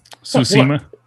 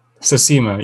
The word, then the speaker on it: Tsushima: Tsushima. – Tsushima, yes.